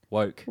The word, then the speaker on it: Wowk: How do you pronounce it? – Woke. – Woke. See, I've probably said Welk.